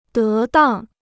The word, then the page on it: 得当: dé dàng